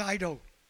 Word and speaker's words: eido: It's the Greek word eido. E-I-D-O. It's a silent E in the Greek.